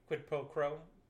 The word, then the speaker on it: quid pro quo: So it was kind of like quid pro quo. Crow? Crow? – Quid pro quo. – Quid pro quo. – Yeah, quid pro quo. – That word? – Said it right.